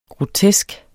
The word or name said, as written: grotesk